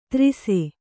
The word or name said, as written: ತ್ರಿಸೆ